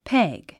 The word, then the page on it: peg: péɡ; ペェグ